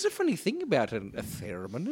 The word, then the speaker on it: theremin: Theremin. – Theremin. – Yes, but don't accent the I too much. Theremin.